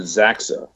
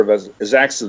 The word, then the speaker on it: Xaxa: I pronounce it Zaxa, X-A-X-A. If anyone has a better pronunciation, speak up. – I think that sounds fine. – All right, I'll call her Zaxa then.